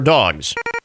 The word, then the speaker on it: Dogue: The menu at Dog, Dog, uh, spelled like dog with a u e at the end to make it seem chic. D O G U E, Dog.